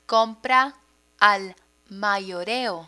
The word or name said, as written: compra al mayoreo